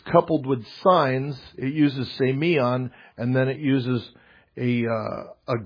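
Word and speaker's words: semion (σημεῖον): First, the word signs, it comes from it's a Greek word here that's pronounced semion.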